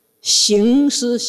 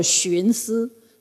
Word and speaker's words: 行思: 我师父叫我寻思，寻呢他不对，你师父是叫你去清源行思，找行思禅师，你的大师兄，清源山行思，行走的行哦。所以叫做我原来不是叫我寻思。所以这个发音不对哦，差了 18000里。